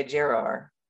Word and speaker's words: Gerar: This is the king of Gerar. I'm just say it. How do you say it? – I mean, in English, you would say Gerar because the E makes the G soft, but.